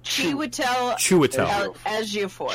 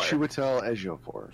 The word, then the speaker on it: Chiwetel Ejiofor: Chiwetel Ejiofor. – Chiwetel Ejiofor.